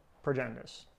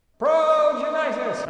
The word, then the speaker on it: Progenitus: Also, maybe someone really wanted Progenitus. – You said it wrong. – Progenitus! – There you go.